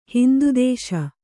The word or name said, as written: ಹಿಂದುದೇಶ